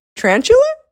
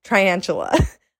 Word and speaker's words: tarantula: One day we were arguing about how you pronounce, I can't say it correct, tarantula? I was so sure that it is pronounced triantula. I always have said like, oh, there's a triantula. Apparently that is not true.